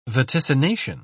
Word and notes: vaticination: British English

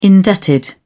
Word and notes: indebted: British English

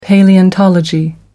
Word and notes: paleontology: American English

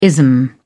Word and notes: ism: British English

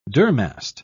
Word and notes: durmast: British English

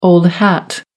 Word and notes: old-hat: American English

